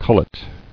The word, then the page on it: Cullet: cul·let